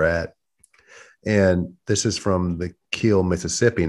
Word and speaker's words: Kiln: Now, it's spelled K-I-L-N, just like the town is spelled K-I-L-N, but they pronounce it the Kill. They don't call it the Kiln or Kiln. – It's not Kiln.